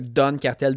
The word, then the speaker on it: Don Cartel: Don Cartel D-O-N Cartel, ça s'écrit comme ça se prononce.